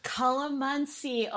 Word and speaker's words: calamansi: Calamansi? – Calamansi. Oh my God. So did I say it right? – You did. Congratulations.